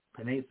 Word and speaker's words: Penei: Penei Sewell.